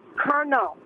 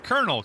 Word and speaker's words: colonel: It's kernel. It's spelled C-O-L-O-N-E-L. – Yeah, but it's pronounced kernel because it makes no sense.